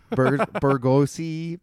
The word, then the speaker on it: bourgeoisie: Is that a word? – bourgeoisie. That's how you pronounce it.